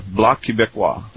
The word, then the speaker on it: Bloc Québécois: Bloc Québécois.